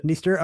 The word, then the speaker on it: Dniester: And Dniester, I love a river with a silent D. – This has a silent D at the triad, right? You might want to pronounce this the Dniester River, but the D is silent.